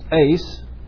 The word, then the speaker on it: eis: that is the Greek word eis, actually correctly pronounced eis, for or because of the forgiveness of your sins, and you shall receive the gift of the Holy Spirit.